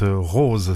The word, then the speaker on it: Rau Ze: La Dérive, le groupe, le duo canadien qui monte, Rose, ça s'écrit R-A-U espace Z-E, et puis à l'instant un classique des B-52s, Love Shack.